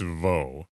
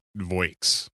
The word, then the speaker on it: voix: So I'm going to say that last part of the word is definitely pronounced Vaux. It is not Voix as it looks.